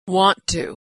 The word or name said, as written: want to